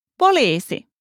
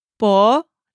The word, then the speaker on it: poliisi: If you need to call the police, shout poliisi. Let's break it down by syllable and hear it one more time. Poliisi. Poliisi. Literally, this means police.